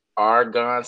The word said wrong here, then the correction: Arbonne: spell it for me. – Arbonne. A-R-B-O-N-N-E. Arbonne. – That's A-R-B-O-N-N-E for the viewers.